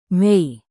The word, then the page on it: mei: Indonesian